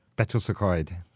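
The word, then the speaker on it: Betws-y-Coed: I can't really pronounce this, but I think it's called Betty's Ecoet. – Betws Ecoet.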